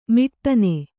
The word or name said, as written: ಮೊಟ್ಟನೆ